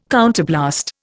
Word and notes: counterblast: Indian

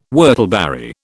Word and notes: whortleberry: British English

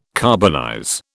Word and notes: carbonize: British English

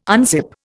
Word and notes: unzip: Indian

